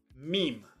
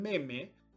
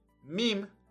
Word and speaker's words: meme: pero sobre todo digo la pronunciación correcta, mim. Y ahí doy, miren, hace 11 años doy la recomendación de no le digan meme, díganle mim, que es lo correcto,